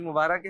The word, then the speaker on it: مبارک: اچھا را کے نیچے زیر ہوتا ہے, رے کے نیچے ہے نا, اصل تلفظ ہے عید مبارک. – اچھا عید مبارک ہے.